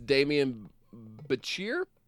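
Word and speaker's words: Demián Bichir: who was it Damien Bichir? I don't know how to say his name. Sorry. – No, you're good. It is Damien Bichir.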